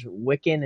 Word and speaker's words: Wiccan: Apparently the colors the boys are wearing when they age up are the same colors as the Young Avengers Wiccan and Speed.